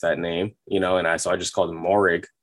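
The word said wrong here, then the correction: Moehrig: or whatever. So Merrick. I've been calling him Morrig but John Gruden pronounced his name the worst though.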